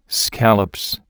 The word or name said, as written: scallops